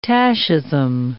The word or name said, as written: tachism